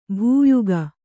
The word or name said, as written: ಭೂಯುಗ